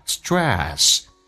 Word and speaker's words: stress: OK，来看一下 stress. TR 发 DR， S 后面跟的是辅音，要浊化一下。 Stress 加个 a 这个发音，下巴往下面掉一点点，叫做 stress.